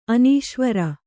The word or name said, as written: ಅನೀಶ್ವರ